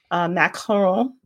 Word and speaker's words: macaron: So for me, what I've found as an alternative that's worked for me is Trader Joe's has these French macaroons, macaron. I guess that's how you say it instead of the other way, because the other one is like the coconut cookies that I don't like. – Macaroons are coconut. Macarons are almond.